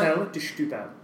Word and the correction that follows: Setúbal: De Stubal. – Stubal. – phonetically, that's basically spelled Setúbal. – Setúbal, yeah. – Moscatel de Setúbal.